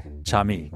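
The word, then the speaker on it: Cham'e: C-H-A-M apostrophe, or yeah, apostrophe E. I. – Chame. – Chami.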